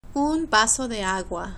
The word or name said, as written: un vaso de agua